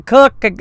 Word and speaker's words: Koch: Coke's Morton facility, and it's spelled K-O-C-H, could be Koch, it could be Cook, you know, it's unusual how to pronounce that name,